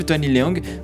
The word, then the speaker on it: Tony Leung: Por mais que o filme de Hong Kong tenha duas das minhas estrelas favoritas do cinema chinês, que é o Tony Long e eu falei que é em francês agora, que tem nada a ver, e, e o Andy Lau. Inclusive, o Tony Leung vai estar tá no, no, no filme da Marvel ano que vem, né?